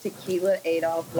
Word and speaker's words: Tequila Adolph: It is pronounced Tequila Adolph, like the drink.